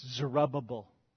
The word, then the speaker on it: Zerubbabel: That's Zerubbabel. That's how you say it.